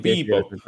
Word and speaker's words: Bebop: kuidas seda hääldatakse eesti keeles? – peaks olema äh, Bebop.